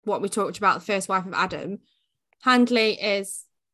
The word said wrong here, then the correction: Hedley: Is it Handley or is it Hadley? Have I just been saying a name wrong this whole time? Hedley. Fucking hell. Hedley.